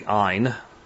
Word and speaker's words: Ayn: This is by Ayn Rand, and a lot of people say Ayn Rand. It's actually Ayn, as though it were I-N-E, or like for mine.